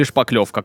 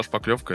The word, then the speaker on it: шпаклевка: Шпатлевка или шпаклевка, как правильно? – На самом деле и так произносится, обычно от слова шпатель, шпатлевка, но я также встречал шпаклевка, это просто вот прям используется везде.